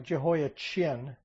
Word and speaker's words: Jehoiachin: To English readers, it looks like Jehoiachin. In Hebrew, it is pronounced with a K sound, so it'd be a keen at the end.